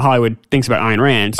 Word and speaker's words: Ayn Rand: I learned how to say her name because Ayn Rand.